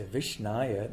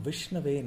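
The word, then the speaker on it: Vishnave (विष्णवे): So if somebody thinks that because it's Krishnaya for Krishna, for Vishnu also I should say Vishnaya, that's wrong. So Dhiro Vadati Vishnave. So Dhira means the learned person pronounces correctly and he says Om Vishnave Namaha, not Om Vishnaya Namaha.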